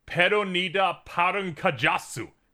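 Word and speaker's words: Pernida Parnkgjas: But upon going to the Bleach Wiki, according to Romanji, they pronounce it Peronida Parunkajasu.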